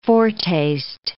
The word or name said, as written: foretaste